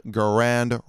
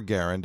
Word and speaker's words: Garand: the legendary M1 Garand rifle, or Garand, depending on how you choose to pronounce it.